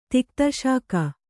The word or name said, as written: ತಿಕ್ತಶಾಕ